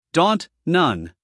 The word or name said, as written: daunt